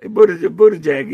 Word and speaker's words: Buttigieg: But, Buttigieg. – Yeah, but Buttigieg.